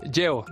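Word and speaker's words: Yeoh: Michelle Yeo. Yeo, vale, vale.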